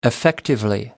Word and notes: effectively: American English

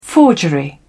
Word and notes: forgery: British English

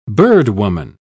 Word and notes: birdwoman: British English

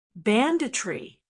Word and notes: banditry: American English

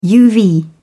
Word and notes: uv: British English

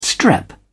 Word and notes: strep: British English

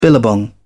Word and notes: billabong: British English

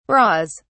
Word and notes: bras: British English

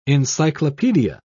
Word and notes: encyclopaedia: British English

